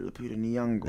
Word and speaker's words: Lupita Nyong'o: How you said that, Lupita Nyong'o. – That's how you say her name.